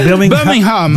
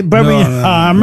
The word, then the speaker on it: Birmingham: On ne doit pas prononcer le G, hein, il paraît. – Birmingham.